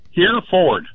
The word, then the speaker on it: Hereford: Here Ford. Hereford, like the cow.